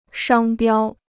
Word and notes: 商标: shāng biāo

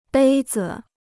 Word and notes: 杯子: bēi zi

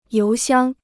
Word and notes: 邮箱: yóu xiāng

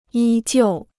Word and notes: 依旧: yī jiù

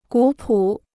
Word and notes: 古朴: gǔ pǔ

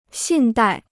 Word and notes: 信贷: xìn dài

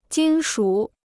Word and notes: 金属: jīn shǔ

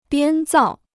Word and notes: 编造: biān zào

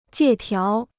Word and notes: 借条: jiè tiáo

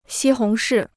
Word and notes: 西红柿: xī hóng shì

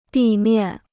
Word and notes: 地面: dì miàn

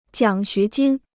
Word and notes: 奖学金: jiǎng xué jīn

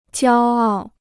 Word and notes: 骄傲: jiāo ào

